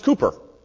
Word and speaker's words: Cowper: He, um, he, he spells it C-O-W-P-E-R, like Cowper, but it's pronounced Cooper.